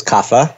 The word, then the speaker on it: Kapha: The third dosha is kapha or kapha. – Kapha. – If you really want to be correct about your Sanskrit pronunciation.